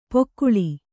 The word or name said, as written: ಪೊಕ್ಕುಳಿ